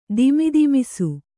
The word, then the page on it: ದಿಮಿದಿಮಿಸು: dimidimisu